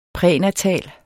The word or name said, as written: prænatal